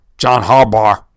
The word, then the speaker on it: John Harbaugh: But this, I'm going to revisit this until John Harbaugh, whatever, however you say his name.